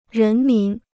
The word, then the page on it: 人民: rén mín